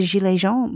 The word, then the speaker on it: Gilets Jaunes: And I think the broader context for this particular backlash, of course, is the Gilets Jaunes movement in France, which, Felix, maybe you can expand upon a little bit. First, by, did I even pronounce that correctly? – I thought it was spectacular.